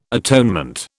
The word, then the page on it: atonement: British English